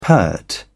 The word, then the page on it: pert: British English